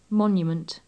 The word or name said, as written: monument